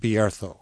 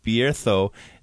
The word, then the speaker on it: Bierzo: I know when I say Bierzo, it's spelled Bierzo to me. – Yeah, well, the Z and the C in Castilian Spanish are pronounced with as TH.